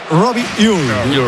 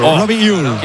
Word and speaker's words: Robbie Ure: A ver, el club pasó incluso un audio, lo ha pasado. Y en concreto sería Robbie Ure. Robbie Ure.